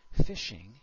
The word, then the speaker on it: phishing: And as I mentioned, this is pronounced like the word phishing, except for in this case, the attacker is phishing for information about you.